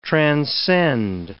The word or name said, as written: transcend